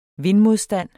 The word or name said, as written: VINDMODSTAND